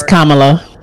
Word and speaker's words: Kamala: It's Kamala.